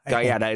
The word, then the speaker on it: Guetta: David Guetta? – Gaeta.